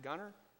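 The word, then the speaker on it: Gungor: One of my favorite artists today is a group by the name of Gunner. Am I saying that right, Matt? Gunger.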